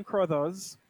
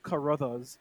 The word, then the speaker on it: Crothers: Scatman Crothers, or as I like to call him, Scatman Carruthers, because that's how all rappers pronounce his name so that they get an extra syllable in.